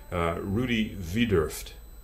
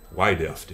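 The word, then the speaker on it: Rudi Wiedoeft: Rudi Widerft, I suppose you say. Wideft, if you were an American, you know. I don't know how he pronounced it, whether he gave it a German pronunciation.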